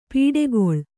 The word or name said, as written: ಪಡುಕುಳಿ